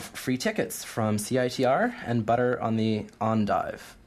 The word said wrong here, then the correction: Endive: Endive. – Why can I never pronounce that right? – You know what?